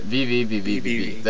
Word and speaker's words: VVVVVV: Like, there's VVVVV. No, did I say that right? VVVVVV. There we go. – Wait, you have to count out whenever you say it.